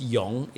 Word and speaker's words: Wing: And it's made even more obnoxious in that in Mandarin, the Wing character, which is pronounced Yong, is pronounced exactly the same, even the same tone.